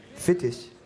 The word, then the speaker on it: Fetisch: Fetisch, fetisch und Strapse.